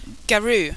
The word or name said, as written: garoo